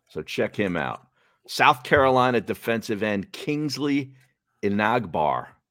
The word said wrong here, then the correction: Enagbare: I'm probably pronouncing that wrong. Enagbare is his last name.